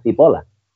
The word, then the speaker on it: cipolla: de hecho, en italiano, que se escribe cipolla, ¿vale? Que es la cebolla. Ellos dicen, ellos dicen, ellos dicen cipola, que es la cebolla.